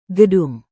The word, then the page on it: gedung: Indonesian